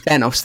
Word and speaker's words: Thanos: Thanos, thank you. And though he's not the main villain here, because Ronan definitely takes that title, it's so fucking badass to see Thanos